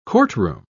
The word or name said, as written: courtroom